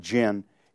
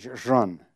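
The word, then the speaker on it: Zhen (仁): The latter is written in the Wade Giles spelling, but the J is pronounced with a sort of a zh sound, so it isn't Jin, it's Zhen. In fact, it's sometimes written R-U-N or R-E-N.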